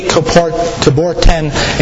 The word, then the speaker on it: Coubertin: Pierre de Corbetin International Fair Play Trophy. Hope no one speaks French here, because I really butchered that up. But the Pierre de Coubertin International Fair Play Trophy, the first trophy was ever given to Eugenio Monti for his fair play and sportsmanship there that day.